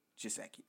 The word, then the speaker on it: Gesicki: How do you pronounce his name? Gesicki? – Mike Gesicki. – Or Gesicki? – Gesicki. – Okay, gotcha.